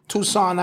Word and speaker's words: Tucson: Tucson, Arizona.